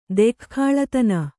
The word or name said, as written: ದೆಖ್ಖಾಳತನ